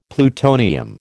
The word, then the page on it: plutonium: American English